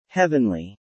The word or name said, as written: heavenly